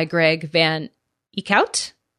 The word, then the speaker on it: Van Eekhout: So we have finished California Bones by Greg Van Eekhout. I said it right. – You did.